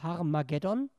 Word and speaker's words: Har-Mageddon: Har-Megiddo, auf Griechisch ausgesprochen, Har-Mageddon.